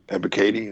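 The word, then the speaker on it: Ebiketie: Is it Ebikati? Ebikati? – I think it's like Ebikati. – Ebikati, that's how you say it.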